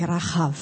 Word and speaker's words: Rahab: Rahab. Ausgesprochen eigentlich Rahab.